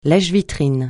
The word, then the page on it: lèche-vitrines: francais